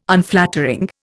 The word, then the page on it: Unflattering: Indian